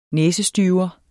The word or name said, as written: næsestyver